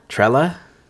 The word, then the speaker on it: Trella: Christmas Party by, I'm not even going to pronounce this, T-R-E-L-L-A. – Trella? – Trella?